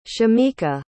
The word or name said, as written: shemeka